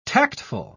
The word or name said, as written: tactful